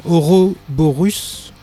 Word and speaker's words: Ouroboros: Non, Ouroboros du groove metal Side Rain. Ouais, ça se prononce comme ça.